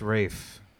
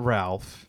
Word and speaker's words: Ralph: It's Rafe. It's not Ralph.